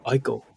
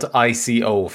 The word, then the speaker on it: ICO: And he'd made a game previously that's called Ico, which I always call Ico. And I know I'm wrong, but I can't. I just get my brain won't. – It's spelled ICO for those people who aren't familiar with it. – Yeah, apparently it's Ico.